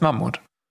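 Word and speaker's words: Mahmut: Nee, also laut, laut, äh, wie er geschrieben wird, er heißt Mammut.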